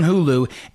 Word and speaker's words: Hulu: It's streaming on Hulu. I keep saying it wrong. It's streaming on Hulu.